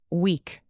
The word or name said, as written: weak